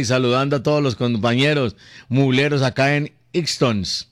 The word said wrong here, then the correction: Easton: ¿Se dice Ixtons? Estados Unidos. – Se escribe Easton. – Easton.